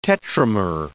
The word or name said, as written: tetramer